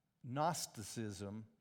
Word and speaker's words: Gnosticism: G-N-O-S, it means knowledge. Gnosticism, the G is silent, taught a couple of radically erroneous things.